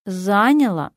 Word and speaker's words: заняло: Обстоятельства, совещание, обсуждение заняло много времени. Заняло, не заняло. Ударение на первый слог.